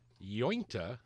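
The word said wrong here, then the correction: Uinta: is actually Uintah. Uintah. It's named after the mountains in Utah.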